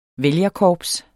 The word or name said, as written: vælgerkorps